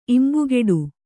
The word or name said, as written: ಇಂಬುಕೊಡು